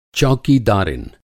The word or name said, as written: चौकीदारिन